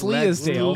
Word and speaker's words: Leadale: I don't think it's Leadale. – Leadale. That's how they say it in the English dub, Ethan.